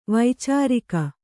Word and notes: ವೈಚಾರಿಕ: vaicārika

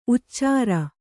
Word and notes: ಉಚ್ಚಾರ: Kannada

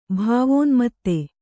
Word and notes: ಭಾವೋನ್ಮತ್ತೆ: Kannada

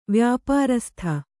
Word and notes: ವ್ಯಾಪಾರಸ್ಥ: Kannada

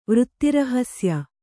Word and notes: ವೃತ್ತಿರಹಸ್ಯ: Kannada

